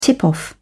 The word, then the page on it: tip off: British English